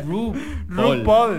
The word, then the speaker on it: RuPaul: Dijo Rapul. – Dijo Rapul, loca. Pero es que yo lo digo a como se lee porque vivan los latinos. – Lo que dijo Rapul. Se escribe RuPaul.